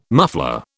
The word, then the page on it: muffler: British English